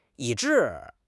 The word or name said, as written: 以至